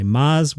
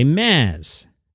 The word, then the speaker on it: Maz: The truth is, my whole life, it's been a struggle to get people to say Maz, which rhymes with Oz. They say Maz, which is like fingernails on a chalkboard for me.